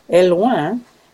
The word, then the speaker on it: Hellouin: Is called La Ferme du Bec et Loin, and that's spelled La, L-A, Ferme, F-E-R-M-E, Du, D-U, Bec, B-E-C. The tricky one is Et Loin, which is the name of the town in France, which is spelled H-E-L-L-O-U-I-N,